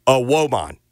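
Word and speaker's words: Evbuomwan: It was indeed a Woman.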